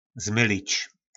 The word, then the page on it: zmylic: po nasimu